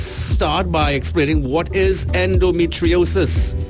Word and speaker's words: endometriosis: Now, today, Dr. Catherine and Danny and myself will be talking about endometriosis. Oh, I got it the first time. – Endometriosis. That's actually really how you pronounce it.